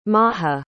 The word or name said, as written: maja